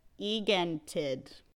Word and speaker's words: Egentid: I believe it's called Egentid. Yep. We speak Swedish. Yep, that's that was definitely not a mispronunciation. It's correct.